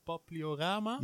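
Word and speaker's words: Papiliorama: Ik vind dat toch best wel interessant, want in Belize, dat doen jullie volgens mij met de Zwitserse vlindertuin Papliorama. – Ja, zeg ik het goed? – Ja.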